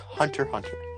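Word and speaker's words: Hunter × Hunter: It's pronounced Hunter Hunter. – But there's an X in between Hunter and that Hunter.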